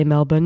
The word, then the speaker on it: Melbourne: I did say Melbourne instead of Melbourne because I've been told I must pronounce it as the Australians do.